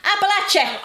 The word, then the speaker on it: Appalachia: Are they from Appalachia? – Is it not Appalachia? – I'm not sure how to pronounce Appalachia, yeah. – It's supposed to be like an apple atchin. – Appalachia!